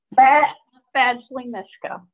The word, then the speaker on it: Badgley Mischka: Badgley Mischka? – Badgley Mischka.